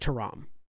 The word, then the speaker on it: Ithrim: It's pronounced Tyrum. Just Tyrum. Because, yeah. Just because you've only ever seen it spelled.